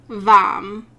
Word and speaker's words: Vam: The mantra is Vam, which rhymes with calm.